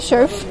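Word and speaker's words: Scherpf: Okay, Stephanie Scherf. Do you want me to spell it? – Yes. – Okay. Last name is S-C-H-E-R-P as in Peter, F as in Frank.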